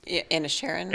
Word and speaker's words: Inisherin: And then he's in The Banshees of Inisherin. Am I saying that right? – Inisherin.